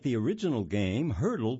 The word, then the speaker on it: Heardle: It's titled, Wordle Launches New Game for Music Lovers Called Hurdle. And that's spelled H-E-A-R-D-L-E.